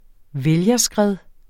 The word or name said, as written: vælgerskred